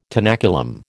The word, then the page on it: tenaculum: American English